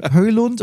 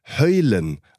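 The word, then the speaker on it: Højlund: Ich würde sagen Höylund, aber du hast ja bei der Europameisterschaft als Stadionsprecher Dänisch gelernt. Wie spricht man den aus? – Wir wurden ja von der UEFA drauf getrimmt, alle Namen in der Muttersprache auszusprechen. Haben dann extra eine App gehabt, wo ein Muttersprachler die ausspricht. Also es wird tatsächlich Höylen ausgesprochen.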